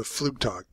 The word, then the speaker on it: Flugtag: All right, so a recent thing you've done is the Flugtag. And am I pronouncing that correctly? – Yeah, I don't speak German, but I we can call it Flight Day.